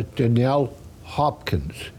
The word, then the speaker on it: Danielle Hopkins: Danielle Hopkins.